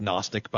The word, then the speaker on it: Gnostic: I know it's pronounced Gnostic, by the way. Just hate to silence the letters, you know.